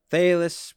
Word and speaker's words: Thales: Well, speaking of this Western philosophy and who started Western philosophy, many credit Thais Homiletius. Definitely did not pronounce that correct.